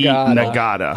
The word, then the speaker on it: Ngata: Nagata. Nada? You say Nada? – I think it's You don't pronounce the G? – You don't pronounce it. – Okay. – The G is silent.